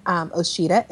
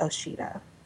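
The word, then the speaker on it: Osheta: And then if you want to tweet at me, you can, I'm on Twitter at Oshita. And my name is spelled O-S-H-E-T-A. I know it sounds like there's a D there, but it's O-S-H-E-T-A.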